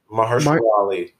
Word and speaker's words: Mahershala Ali: Mar- Mahershala Ali.